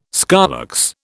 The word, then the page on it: scolex: British English